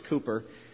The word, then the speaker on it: Cowper: and his name is spelled as if it were pronounced Cowper, C-O-W-P-E-R, but it's Cooper,